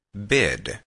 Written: bid

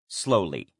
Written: slowly

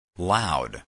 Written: loud

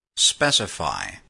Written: specify

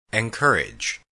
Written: encourage

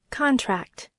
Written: contract

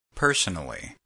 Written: personally

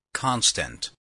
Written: constant

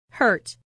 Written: hurt

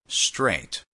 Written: straight

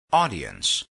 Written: audience

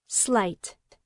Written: slight

